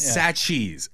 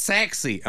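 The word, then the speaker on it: Sachse: And this lady calls and she's like, excuse me. It's sexy.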